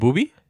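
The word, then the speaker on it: Boobie: Bobby Gibson. – Who's that? – Daniel Gibson. – You mean Booby? – Yeah, Booby. Sorry, I said it wrong.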